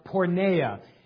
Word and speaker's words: porneia: Now, the Greek word used here is pronounced porneia.